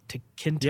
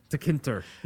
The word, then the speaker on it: Tkinter: That's spelled T-K-I-N-T-E-R, Tinker. – Yeah. To Kinter, yeah. – Are you sure that's? – That's how it's spelled. – Yeah. Okay. – And it's a cross-platform Python GUI. – It's TK module. Interface, is what it's short for.